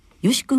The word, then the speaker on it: 義: よし君は大変ね珍しい名字でいらっしゃいますね。すね義理の義と書いてよし君ですね。